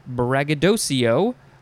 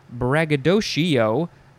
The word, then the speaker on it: braggadocio: It can be said as braggadocio, braggadocio, braggadocio, braggadocio, or braggadocio. I emphasize some of the wrong syllables just to show the differences, but basically it's Shio, Co, Shio, Chio, Sho, or Cho at the end.